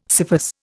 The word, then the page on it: scyphus: Indian